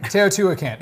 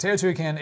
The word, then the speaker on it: Teotihuacan: Teotihuacan. Yeah, that's how you pronounce it. Teotihuacan, age, size, and complexity can make it seem otherworldly, but it's very much the work of humans.